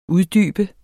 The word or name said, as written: uddybe